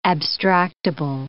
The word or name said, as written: abstractable